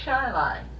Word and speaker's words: Chili: And there's a town, C-H-I-L-I. They told us, well, you go to Shiloh and turn. Well, there it's just like Chile, only it's Shiloh.